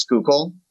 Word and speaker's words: Schuylkill: It's a neighborhood right along the Schuylkill River, which for anyone, if you've ever seen it and didn't know how to pronounce it, it's Schuylkill, S-C-H-U-Y-L-K-I-L-L.